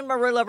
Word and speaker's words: marula: Now, am I saying marula right? – You're saying it right, and I have a very funny story for you that I think your listeners will enjoy.